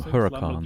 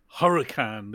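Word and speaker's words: Huracán: I call them Huracans. – Lamborghini Huracan, there you go.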